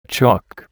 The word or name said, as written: chalk